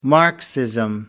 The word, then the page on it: marxism: American English